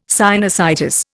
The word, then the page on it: sinusitis: Indian